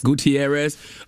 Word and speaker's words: Gutierrez: Am I pronouncing his last name right? Joe Gutierrez? – Gutierrez.